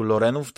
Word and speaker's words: Lorenów: Bo cała historia rodu Lorenów, dobrze wymawiam nazwisko, tak? – Mhm, tak.